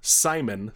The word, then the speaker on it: Psimon: It's Queen Bee and Simon, spelled P-S-I-M-O-N, like psychic.